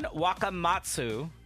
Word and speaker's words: Wakamatsu: They've even hired a legit manager, former Seattle Mariners manager Don Wakamatsu.